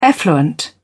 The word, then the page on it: effluent: British English